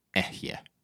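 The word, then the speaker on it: Ehye (אֶהְיֶה): Basically, what goes on here is the word for I am most likely is a word that sounds something along the lines of pronounced like this, Ehye. That means I am.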